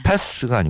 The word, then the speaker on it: path: Path가 아니고 path. – path. – 예, 그렇죠. – 번데기 발음.